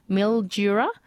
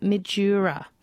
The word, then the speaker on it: Mildura: Mildura, not Midura.